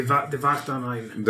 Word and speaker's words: Die Wacht am Rhein: Die Wacht am Rhein. – Uh, uh, w is pronounced as a V. – Yeah. So Devatanein.